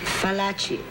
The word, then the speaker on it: Fallaci: Fallaci, F like flower. Not V like Venice.